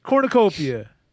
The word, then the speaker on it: cornucopia: Well, like I said, the first syllable is corn. – Corn. – Okay. It's like... – Okay. I just get it. How many... – It's five syllables. – That's huge. – Corn. The last few syllables is copia. Put those three together and what do you have so far? – Cornopia? – No, the last few syllables is copia. – Copia. Corn. – Copia. – Oh, copia. – There it is! Cornucopia.